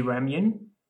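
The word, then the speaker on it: Ramyun (라면): So ramyun, never say ramen to Korean because it'll remind them of the history with the Japanese.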